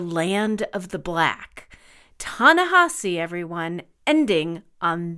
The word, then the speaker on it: Ta-Nehisi: The journalist Ta-Nehisi Coates, T-A-N-E-H-I-S-I, pronounced Ta-Nehisi.